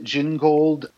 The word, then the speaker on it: Gingold: I've pronounced it Gingold because it sounds like gin.